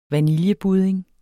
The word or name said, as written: vaniljebudding